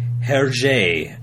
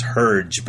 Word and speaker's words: Hergé: Peter Jackson and Steven Spielberg, based on the books and the cartoons of Belgian artist Hergé. I've heard it pronounced Herge, but that's a total anglicized bastardization.